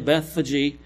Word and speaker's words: Bethphage: And if you look up Bethphage, that's how you say it, Bethphage, you'll see that it's really the outermost part of the city, really, where they could bake bread to bring into the temple.